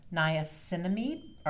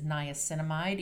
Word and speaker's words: niacinamide: You can also look, you'll see vitamin B3 is often called niacinamide, or you'll hear many people refer to it as niacinamide, either way that you pronounce it.